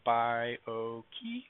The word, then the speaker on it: Byogi: It's got three syllables. I say it as Byogi, and just giving you some phonetic way of remembering how to say it. You can call me Professor Byogi if you want, or you can feel free to call me Mike.